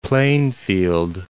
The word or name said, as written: Plainfield